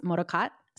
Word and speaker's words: Motocot: So Motocot is an Asian American soft goods company that's based in San Francisco, and it's pronounced Motocot. So perfect, Avery. I love, love, love hearing people say the word Motocot, because it's actually a phrase in my native Chinese dialect.